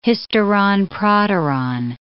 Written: hysteron proteron

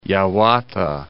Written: Yawata

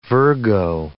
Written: Virgo

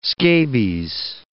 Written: scabies

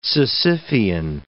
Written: Sisyphian

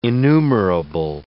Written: enumerable

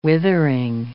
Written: withering

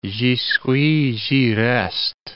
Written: j'y suis, j'y reste

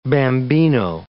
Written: bambino

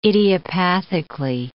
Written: idiopathically

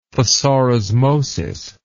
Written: thesaurismosis

